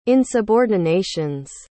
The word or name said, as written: insubordinations